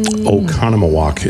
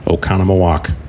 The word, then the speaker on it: Oconomowoc: Oconomowoc is the name of the town. Five O's. Oconomowoc. Spell it and use nothing but O's.